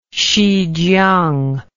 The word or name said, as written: xijiang